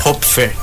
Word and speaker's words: Hopfer: Falei certo teu sobrenome, Leona. Hopfer.